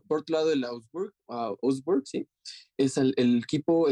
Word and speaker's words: Augsburg: Augsburg. Augsburg.